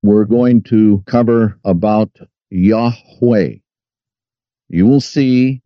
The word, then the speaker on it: Yahweh: This is where you get what is called the tetragrammaton, which means four letters, 1, 2, 3, 4, Y-H-V-H. Also, it is spelled Yahweh, Y-H-W-H. It is also pronounced Yahweh with the W-A-H on the end. It is also pronounced Yah, Y-A-H.